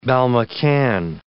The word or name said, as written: balmacaan